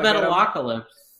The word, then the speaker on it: Metalocalypse: What about the Metalocalypse? – Yeah, there we go.